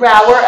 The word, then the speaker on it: Rower: We pronounce it Roer LLC, Lee, but I'll take it. I'll take the rest of what you said. – Yeah, we pronounce it Roer. We pronounce it Roer.